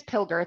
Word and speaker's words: Pilger: So my last name is Pilger. It's a hard G. – Oh, but I'm sorry. – No problem at all. Jane Pilger.